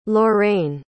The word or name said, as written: Lorrayne